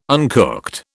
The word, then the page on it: uncooked: British English